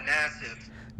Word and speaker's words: Nassif: How do you pronounce that? – Yeah, Nassif. – Nassif. Okay, well, that makes sense because that's exactly how it's spelled.